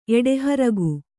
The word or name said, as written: ಎಡೆಹರಗು